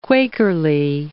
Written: Quakerly

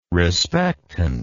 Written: respectant

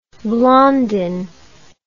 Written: Blondin

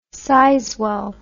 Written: Sizewell

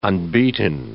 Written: unbeaten

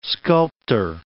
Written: sculptor

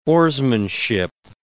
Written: oarsmanship

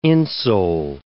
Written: insole

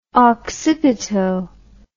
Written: occipito

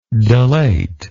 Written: delatorian

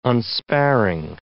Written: unsparing

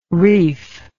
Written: reif

